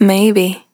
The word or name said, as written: Maybe